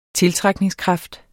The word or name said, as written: tiltrækningskraft